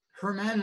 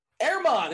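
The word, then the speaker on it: Germán: Can we pronounce his name correctly just one time? – Herman Marquez? That's wrong? – Stevie knows it, I think. Maybe. Maybe I'm setting them up here. – I thought it was Marquez, too. – Airmon. It's Airmon Marquez.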